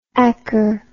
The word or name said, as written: acker